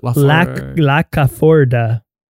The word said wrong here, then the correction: Lafourcade: There's no C in it though. L A F O U R C A D E. Laforcade.